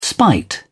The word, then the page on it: spite: British English